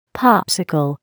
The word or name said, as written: popsicle